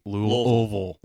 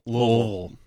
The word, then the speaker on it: Louisville: Louisville. Louisville. It's like a half syllable.